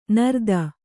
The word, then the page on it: ನಿರ್ಧನ: Kannada